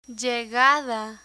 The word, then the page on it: llegada: Spanish